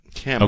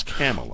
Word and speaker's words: Kamala: Kamala. – Okay, thank you. – It's Kamala.